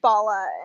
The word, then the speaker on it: Bala: Yeah, so it's pronounced Bala.